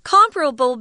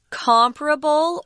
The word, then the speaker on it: comparable: This word can be pronounced two different ways comparable or comparable. I think comparable is probably used far more, but you can pick which pronunciation you're comfortable with.